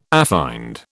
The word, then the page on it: affined: British English